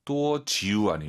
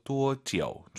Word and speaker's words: 多久: 多久 아니고 아이유 사이에 오 넣어서 多久.